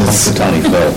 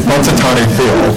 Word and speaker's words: Punxsutawney Phil: Punxsutawney Phil. – Thank you. Punxsutawney Phil.